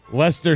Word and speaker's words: Leicester: Leicester City, 1.